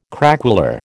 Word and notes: craquelure: American English

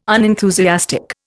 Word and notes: unenthusiastic: Indian

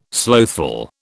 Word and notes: slothful: British English

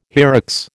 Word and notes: hyrax: American English

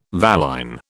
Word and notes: valine: British English

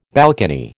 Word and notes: balcony: American English

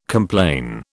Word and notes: complain: British English

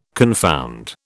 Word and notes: confound: British English

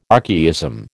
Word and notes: archaism: American English

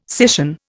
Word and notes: scission: Indian

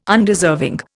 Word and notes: Undeserving: Indian

